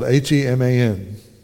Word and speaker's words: Heman: So the singers, and here's one of those names, spelled H-E-M-A-N. Ray Hughes pronounced this as He-Man.